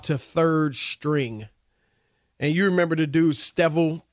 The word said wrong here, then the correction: Streveler: What, how do you say his name? Rob G? Streveler? Yes, Streveler will be the backup.